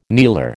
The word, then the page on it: kneeler: American English